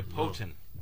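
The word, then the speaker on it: poutine: and if you can let us know how to pronounce P-O-U. – I told you, Poutin. – I'm getting a lot of poutines.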